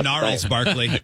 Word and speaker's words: Gnarls Barkley: And I'm thinking the audio clue is the song Crazy by Genghis Barkley or whatever. I can't really pronounce it. Gnarls Barkley.